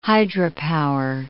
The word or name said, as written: hydropower